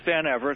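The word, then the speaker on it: Van Evera: And on that note, I want to I want to welcome Stephen Van Ivera, but please correct me if I mispronounced your name, sir. – It's Van Ivera. It's close enough.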